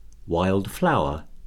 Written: wild flower

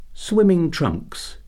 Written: swimming trunks

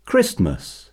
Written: Christmas

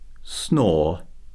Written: snore

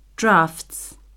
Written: draughts

